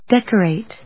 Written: decorate

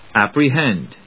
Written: apprehend

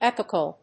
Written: epochal

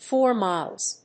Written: for miles

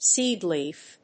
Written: seed leaf

